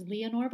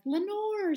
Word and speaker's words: Leanor: I'd like to say it happened because nobody can pronounce my real name, which is Leanor, but I always get like, Lenore, sign my CD, right. Lenore.